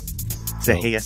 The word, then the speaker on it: Sereia: Seheia Sentimental, but it's spelled S-E-R-E-I-A and then sentimental.